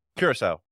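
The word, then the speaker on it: Curaçao: And what was interesting about the Panama-Caraco game, which Caraco won 1-0. Am I saying that incorrectly? – Curacao. – Thank you. Curacao game. – Looks like Caraco.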